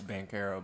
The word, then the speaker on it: Banchero: Oh my god, nigga, Bonchero is like that. Yeah, and I got his name right. You punk motherfucker. – Yeah, he was a Niso, so you know. – It's Banchero, but it's okay.